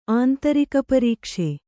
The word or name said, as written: ಆಂತರಿಕಪರೀಕ್ಷೆ